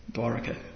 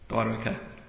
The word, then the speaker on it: Dwarka: And these things were going on simply so that Akrura could be brought back into Dwarka again. Akrura explained, had actually left Dwarka. How do you pronounce it properly? Dwarka. I always never get that one right. Dwarka. Okay. Dwarka.